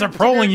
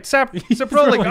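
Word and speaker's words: Saproling: It was like, it's Saprolling, you moron. – And then make sure you say it right. Saprolling. Oh, crap. – The look on your face. – I was like, hold on. We got to stop here. I just want to hear you. I thought we were going to have an argument. You're like, this is how you say it. But you're immediately like, it's Saprolling. – Yeah, no, it's Saprolling. Sorry.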